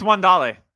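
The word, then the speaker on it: Wan'Dale: It's Wandale. – It's Wandale. The New Yorkers say it's Wandale, and so it's Wandale. – I think he's Italian, and that's why it's Wandale.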